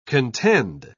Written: contend

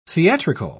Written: theatrical

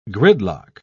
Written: gridlock

